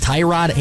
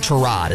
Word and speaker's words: Tyrod: Actually not, because I asked him that question, and he said both, Tyrod and Tyrod.